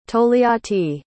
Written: tol'yatti

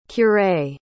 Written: curé

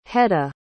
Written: hedda

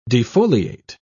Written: defoliate

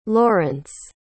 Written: lawrence

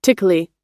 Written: tickly

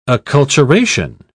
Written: acculturation

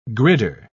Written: gridder

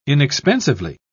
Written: inexpensively

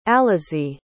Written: alezae

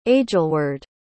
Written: aegelweard

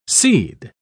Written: cede

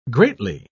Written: greatly

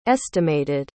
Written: estimated

